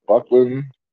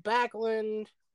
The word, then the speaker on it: Backlund: Yeah, you've got Buckland. Kadari. I'm trying to pronounce some of these names. – Here, I'll help you. Let me get the roster pulled up. Let's go. Backlund.